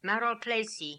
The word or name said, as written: Maralpleicie